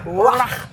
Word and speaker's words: Roy: Patrick Roy. Pronounced properly? – That's okay for today. – Say it. – Patrick Roy. – Roy. I love it.